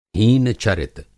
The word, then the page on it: हीन-चरित: Hindi